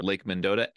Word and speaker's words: Mendota: and you guys would have to correct me on my pronunciation here, Lake Mendota and Lake Monona. Did I get those right? – Yep, nailed it.